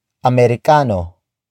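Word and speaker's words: Amerikano: Americano, si, Roger.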